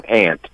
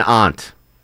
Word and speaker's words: aunt: And it is an ant in this state. – Not an aunt.